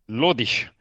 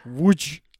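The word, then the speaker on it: Łódź: Mis sa arvad, kuidas seda hääldatakse Poola keeles? – Lodish. – Ei. Vudž. – Vudž.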